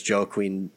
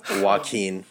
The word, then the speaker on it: Joaquin: So Jack Nicholson's Joker adaptations, the Joker's name was Jack Napier, whereas Joaquin, however you say it. – Joaquin well, Phoenix's Joker.